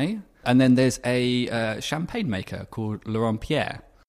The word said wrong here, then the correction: Laurent Perrier: Laurent Perrier that one.